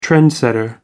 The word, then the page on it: trendsetter: American English